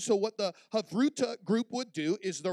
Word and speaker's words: Havruta: In Jewish tradition, what they would do when the rabbis would begin to teach, they would practice what they call Havruta. Everybody say Havruta. Now, if you're taking notes, that's spelled H-A-V-R-U-T-A, Havruta.